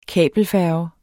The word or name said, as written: kabelfærge